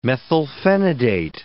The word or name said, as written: methylphenidate